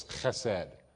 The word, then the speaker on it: chesed: In Hebrew, it's pronounced sort of like this, chesed.